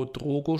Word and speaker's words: Drogo: der den Drago Drogo spielt.